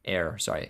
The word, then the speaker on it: heir: She married like the heir to like some like heir or heir. – Air. – Oh, sorry. Okay. I thought I was saying it wrong.